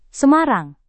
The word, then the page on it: Semarang: Indonesian